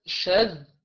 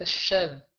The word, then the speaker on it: الشاذ: الحديث الشاذ، الشاذ مش الشاذ، طيب، الشاذ الذال تنطق هكذا ذ وليس ذ.